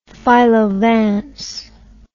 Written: philo vance

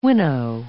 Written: winnow

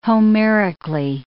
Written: Homerically

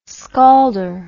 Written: scalder

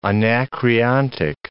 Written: anacreontic

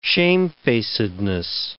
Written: shamefacedness

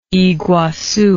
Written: iguassu

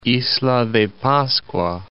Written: Isla de Pascua

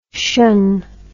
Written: cion

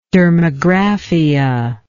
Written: dermographic

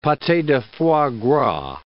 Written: pate de foie gras

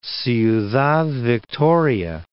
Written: Ciudad Victoria

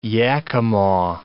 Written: Yakama

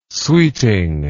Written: sweeting

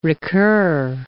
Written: recur

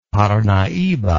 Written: parnaiba

